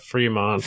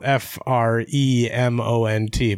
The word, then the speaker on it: Fremont: Fremont. – Fremont. Okay, it's spelled F R E M O N T, but it's Fremont.